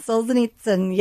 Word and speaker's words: Solzhenitsyn: Solzhenitsyn, yes.